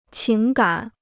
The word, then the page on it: 情感: qíng gǎn